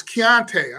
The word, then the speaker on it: Konata: Is it Kanata Mumfield? Is that how you say his name? – Kanata. – Kanata Mumfield. – No, it's Keontae. I'm sorry. – Keontae? – Yeah. – K-O-N-A-T-A. Pronounced Keontae.